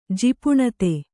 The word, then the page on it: ಜಿಪುಣತೆ: Kannada